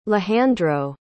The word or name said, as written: lehandro